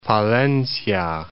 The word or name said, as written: Palencia